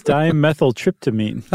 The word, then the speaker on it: dimethyltryptamine: It's dimethyltryptamine.